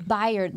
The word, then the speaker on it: Byard: Byard. – Byard.